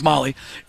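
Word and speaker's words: Mahle: It's pronounced Molly, and it looks like male. It's Molly.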